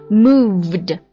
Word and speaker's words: moved: 然后这个 move 动加了 ed 之后啊，它有 e 就只加 d 啊，所以它读作 moved，因为它最后结尾的是 v 音嘛， v 它是轻辅音 f 的一个对应的浊辅音的 v。